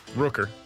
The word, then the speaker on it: Rooker: By the way, Rocker play last night? – Yes. Rooker.